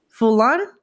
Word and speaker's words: Foulon: We have Christophe Foulon, and I hope I pronounced the last name right.